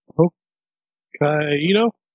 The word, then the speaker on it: Hokkaido: Hokkaido.